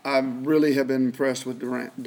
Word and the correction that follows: Dorance: Dorance. It's Dorance. It's pronounced Dorance, but um, he's from down there in, in, Houston, Galena Park, North Shore, storied program, and he can rush the passer now.